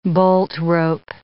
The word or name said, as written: boltrope